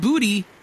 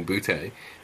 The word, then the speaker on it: Booty: Yeah, we actually had to look it up how to pronounce it because we're both saying "butte," which is incorrect. He says it himself, "booty," so it's Kayshawn Booty.